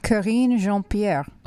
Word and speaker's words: Karine Jean-Pierre: Karine Jean-Pierre.